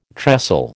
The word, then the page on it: trestle: American English